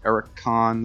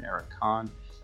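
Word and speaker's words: Erik Conn: Have been doing it so long, and Magnet School, I had an interview after the live show with them with Eric Kahn, the drummer. That's spelled E-R-I-K, last name C-O-N-N, Eric Kahn, drumming machine that he is.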